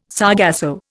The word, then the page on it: sargasso: Indian